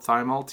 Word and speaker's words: Thymol: It's spelled Thymol, T-H-Y-M-O-L, but we're supposed to say Thymol because it's thyme oil.